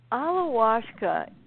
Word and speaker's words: ayahuasca: So I know in your book that you channel ayahuasca.